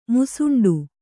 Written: ಮುಸಂಡಿ

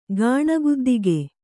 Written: ಗಾಣಗುದ್ದಿಗೆ

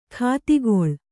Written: ಗತಿಗೊಳ್

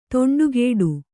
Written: ದಿಂಡುಗೆಡೆ

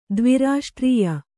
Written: ದ್ವಿರಾಷ್ಟ್ರೀಯ

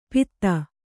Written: ಪೀತ

